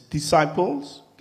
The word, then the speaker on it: disciples: It's D, three syllables. Disciples. You don't say it like you spell it.